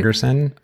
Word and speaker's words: Torgersen: So for those who don't know, we're talking about, and I'm going to try to say his name, Mads Torgersen. Did I get that right? – Torgersen. – Okay. We'll go with your pronunciation.